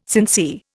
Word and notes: sincere: Indian

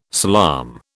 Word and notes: salaam: British English

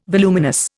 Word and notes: voluminous: Indian

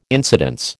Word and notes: incidence: American English

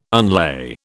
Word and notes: unlay: British English

